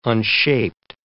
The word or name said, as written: unshaped